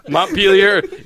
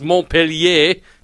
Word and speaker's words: Montpelier: Montpelier, even though in my opinion it should be pronounced Montpellier, since it's a French word, but...